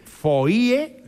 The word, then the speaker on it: foie: Foie, que es una palabra francesa que a mí siempre me ha llamado mucho la atención porque se escribe foie... Sí. Y se pronuncia foie. Fíjate, la O, la I y la E se escribe. Y luego se pronuncia con la U.